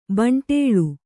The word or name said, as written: ಭಂಡೀಲ